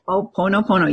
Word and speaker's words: Ho'oponopono: It's an ancient Hawaiian healing art called Ho'oponopono. Did I pronounce that right? – Ho'oponopono. Yes.